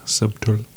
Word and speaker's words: subtle: He pronounced the B in subtle twice in the whole book. Subtle.